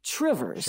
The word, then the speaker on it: Two Rivers: I mean, it's spelled like you can imagine, T-W-O-2, and then Rivers. But locals might say Trivers.